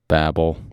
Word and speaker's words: Babel: But uh, apparently, that hideous strength is a refer- reference to the Tower of Babel or Babel. How do you say it? Babel. Okay, Tower of Babel.